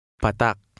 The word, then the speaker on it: Patak: When the stress is on the final vowel, the vowel sound is not lengthened. Read number 5. – Patak. Patak.